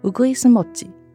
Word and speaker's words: Uguisumochi: 譬如在第 12集《初春的味道》讲到两种都叫做樱饼的核果子，因为中文发音相同，所以我们特别请主播在华语后面加上日语的发音 Uguisumochi 和 Sakuramochi，好让有声书的读者能区别两者的不同，理解上更加清楚。